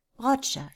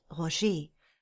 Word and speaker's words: Roger: So wie der beste Schweizer Tennisspieler gerufen wird, Roger statt Roger, nur eine kurze Betonung auf der ersten Silbe, statt zusätzlich auch noch das lange E am Wortende zu betonen.